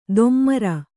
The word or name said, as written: ದೊಮ್ಮರ